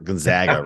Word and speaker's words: Gonzaga: It's not Gonzaga, right? It's Gonzaga. – Yeah, am I getting it right? Gonzaga. They're you know, though, one of the interesting kind of ways to describe it was you don't zog, you zag. So zigzag, Gonzaga.